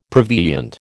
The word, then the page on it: prevenient: American English